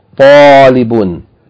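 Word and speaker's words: Talibun (طَالِبٌ): Hadha talibun. The ta should be distinguished from ta. It's not talib, it's talib. Ta, fatta, alif, long vowel, lam, kasra, short vowel, ba with tanween. Three syllables. Talibun.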